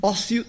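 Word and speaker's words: ósseo: E ócio de ossos, que se escreve dois S e um E, mas soa exatamente igual.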